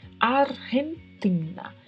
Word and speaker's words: Argentina: O G seguido do E é pronunciado com um som que vem da garganta. Argentina, Argentina. E o TI, assim como o DI, não é pronunciado como TI, e sim como TI. Portanto, vamos pronunciar sempre Argentina.